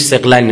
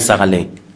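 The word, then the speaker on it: ثقلین: دو تا چیز، سقلین، درسته این تلفظش سقلین نه سقلین، سقلین یعنی جنوئنس، سقلین یعنی دو چیز گرانبهای هم وزن.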